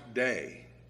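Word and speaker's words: day (δεῖ): It's the Greek word, it's pronounced day. If we spell it in English, it's D-E-I.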